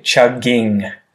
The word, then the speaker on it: chugging: One more little note there, and I'm sure you'll notice when you re-listen to this episode, is that we don't literally say chugging. So you'll miss that. In the pronunciation of chugging, when we use that form of the verb, it just gets turned into a kind of muted n sound at the end. So chuggin, chuggin, chuggin, chuggin.